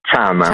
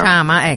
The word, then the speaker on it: Chama: Allora, cosa vuol dire Chama in Swahili? A parte che non so se l'ho detto giusto. Ecco, per io cui... credo che si pronunci Chama. – Chama, ecco, allora correggiamo Chama.